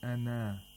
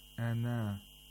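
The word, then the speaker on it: أنا: It means that it's only pronounced with alif ul mad whenever you pronounce this word alone. Ana, ana. If you stop on ana, it will become ana.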